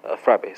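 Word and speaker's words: frappes: Spelled C-O-L-P-I-S-C-I. French speakers translate the Italian to the French. It's going to be frappe, spelled F-R-A-P-P-E-S. S is silent.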